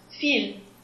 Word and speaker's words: fil: And if you have talking about um, a thread or a cable, then it would be pronounced fil.